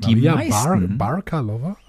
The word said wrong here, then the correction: Bakalova: heißt sie? – Bakalova. Okay. Ich weiß nicht, wie sie richtig ausgesprochen wird.